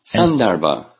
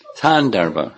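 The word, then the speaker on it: Sandarbha (सन्दर्भ): Sandarbha. – Yeah. – It's, I'm used to hearing it with accent on the second syllable. Sandarbha. Maybe it's not Thandarbha.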